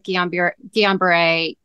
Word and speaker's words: Guillain-Barré: I know you were diagnosed with Guillain-Barré a while ago.